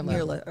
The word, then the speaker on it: Mirla: Look at someone like Mirla. Am I saying her name right? Mirla? I don't want to say her name. Mirla. – Mirla, okay.